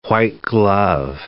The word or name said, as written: white-glove